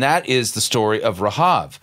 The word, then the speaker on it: Rahab: And that's the Hebrew way, that's how you pronounce her name in Hebrew, Rahab. It's not Rahab. That's the, every time we hear somebody say Rahab, it's like, I do that. It's a Rahab. That's how her name is pronounced.